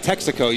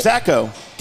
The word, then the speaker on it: Texaco: Texaco. – Oh, there you go. There it is. He's always on it, didn't he? – You put the emphasis on the wrong syllable.